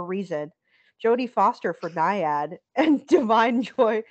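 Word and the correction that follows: Da'Vine Joy Randolph: Well, it's actually, apparently it's Davine Joy Randolph. I've been pronouncing it wrong completely for ages now.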